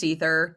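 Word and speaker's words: Aether: Also, ether, I know it's spelled with an A, but it's also an anesthetic. So that's cool. – Mm-hmm. That was so annoying where they could have just spelled it ether. It's pronounced ether. Just spell it ether.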